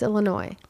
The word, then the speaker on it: Illinois: The last S is not pronounced. – Then why is it there? Why isn't it? – Why is there a K on no? – Jeez. Okay, fine. It's Illinois.